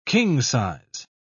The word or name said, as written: king-size